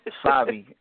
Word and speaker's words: Fabi: Fabi, F-A-B-I.